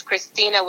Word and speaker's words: Cristina: Well, you can find me everywhere at Savannah Cristina. So that's Savannah spelled like the city, S-A-V-A-N-N-A-H. And that's Cristina with no H, C-R-I-S-T-I-N-A.